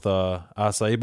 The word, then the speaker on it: acai: And also I'm coming out with, acai bowls. Have you ever tried one of those? – No. Spell it. – It's A-C-A-I, acai.